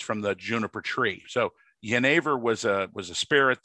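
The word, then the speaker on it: jenever: Uh, it starts with a letter J. I think it's correctly pronounced Yenever.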